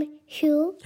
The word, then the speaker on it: Hugh: Alors oui, la vraie prononciation ultime, c'est Hugh parce qu'en anglais, on dit Hugh Jackman et pas Hugh Jackman. Bref, c'est un, un, nom, Hugh.